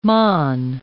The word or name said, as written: mon